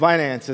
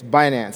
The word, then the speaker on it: Binance: So the origin of Binance is we have a really cool name. It's Binance. So like it's not Binance, you know, as some people pronounce it. So it's binary finance.